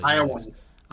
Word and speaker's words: Iowans: And Iowa ends or Iowans? How do you say that? You're the Iowans. – Iowans. There we go.